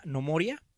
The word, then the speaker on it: Gnomoria: Les recomiendo un juego que se llama Nomoria. Uh-huh. Se escribe Gnomoria.